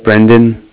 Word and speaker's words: Brendan: So my name is Brendan. And that's spelled B-R-E-N. D-A-N, and I'm calling with Cardiff, and that's spelled C-A-R-D-I-F-F, like Frank.